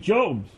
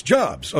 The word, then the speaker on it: Jobs: You know, it's Steve Jobs. – All right, Steve Jobs. I mean, he's what? It's Jobs. Okay, all through show prep, everybody was saying Jobs. – No, when they were saying Jobs, you didn't hear about it.